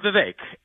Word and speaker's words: Vivek: So I'm Vivek. It rhymes with cake, and I have lived the American dream.